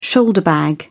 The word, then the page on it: shoulder bag: British English